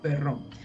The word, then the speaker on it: Perron: No sé cómo se pronuncia en inglés, pero en español se dice Perrón.